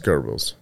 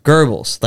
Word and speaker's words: Goebbels: It's pronounced Goebbels. – Goebbels, like the food for babies.